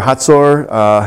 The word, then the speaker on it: Hazor: God sold them into the hand of Jabin, king of Canaan, Yabin, and pronounced in Hebrew, who reigned up in Hazor or Hatzor.